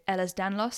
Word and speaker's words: Ehlers-Danlos: So as you mentioned, you've been living with joint issues and you've talked a lot on Instagram about living with Ehlers-Danlos syndrome.